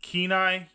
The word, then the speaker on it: Kenai: Kenai, you said is how it's pronounced? – Yep.